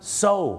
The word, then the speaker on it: sew: ออกเสียงไงนะครับ SEW เชิญเลยครับอะไรครับซิลหรอครับไม่ใช่ซิลครับผมซูโอไม่ใช่นะฮะไม่ใช่ครับซูเนี่ยนะครับภาษาอังกฤษเนี่ยนะครับจะเป็นชื่อผู้หญิงหรือจะแปลว่าการฟ้องร้องคดีก็ได้ครับคำนี้ออกเสียงว่าอะไรไหมครับโซถูกต้องแล้วครับ SEW ออกเสียงว่าโซครับ